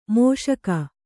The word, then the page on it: ಮುಷ್ಕ: Kannada